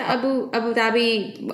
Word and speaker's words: Abu Dhabi: You guys just, how long ago did you move to Abu Dhabi? Am I pronouncing it correctly? – Yeah. We moved to Abu Dhabi almost 5 years ago.